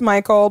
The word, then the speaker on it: Michiel: Also, his name is Michael, but spelled like Michiel, M-I-C-H-I-E-L.